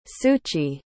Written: suchi

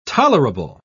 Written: tolerable

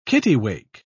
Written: kittiwake

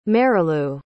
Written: Marilu

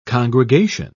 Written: congregation

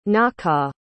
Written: nawkaw

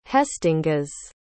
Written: haestingas

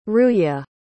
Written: ruya

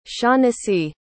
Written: shaughnessy

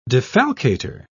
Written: defalcator

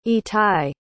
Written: itai

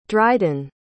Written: dridan

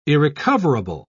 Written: irrecoverable

